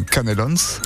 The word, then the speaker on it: canelons: On y dit même canelons. – Canelons. – On dit pas on prononce toutes les lettres.